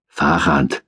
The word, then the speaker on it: Farchant: Farchant. Betonung auf der langen ersten Silbe, du Press.